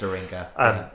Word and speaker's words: Aringa: I don't know if I'm saying his name right at all. – It is Aringa.